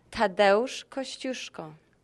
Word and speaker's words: Tadeusz Kosciuszko: She watches the movie, she peers into the exhibit, and then she tells me I've been pronouncing the man's name wrong. – Tadeusz Kosciuszko. – Kosciuszko.